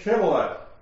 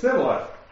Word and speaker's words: shibboleth: If he said no, then they would say to him, then say Shibboleth. And he would say Sibboleth, for they could not pronounce it right.